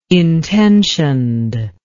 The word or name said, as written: intentioned